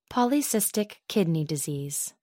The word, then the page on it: polycystic kidney disease: American English